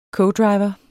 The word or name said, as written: codriver